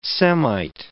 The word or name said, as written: Semite